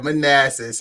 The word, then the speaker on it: Manassas: Yeah, Manassas, good old Manassas.